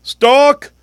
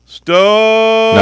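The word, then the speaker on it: Stōk: So I think you're supposed to pronounce it Stalk. – No, I think it's just a symbol that says it's a long O. You're supposed to make the O sound.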